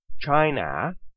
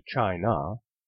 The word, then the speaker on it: China: Consider the word China with a short A sound compared to China with the correct schwa sound.